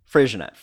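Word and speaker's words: Freixenet: If you've ever found yourself in the store wondering how to pronounce the wine with an X in the middle of it, it was probably Frisianette, where the X is pronounced as an SH.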